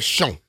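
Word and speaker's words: Chão: Ike Dog on Twitter just said, it's like the Fogo people when you mispronounce Chow. What... Fogo de Chow. – Fogo de Shown, they said. That's what it is. – De Shown? – Yeah, that's what I've heard. But I'm like, that's C-H-O. – C-H-A-O. – C-H-A-O.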